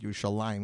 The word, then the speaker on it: Yerushalayim: They don't say it Yerushalayim. He says Yerushalem.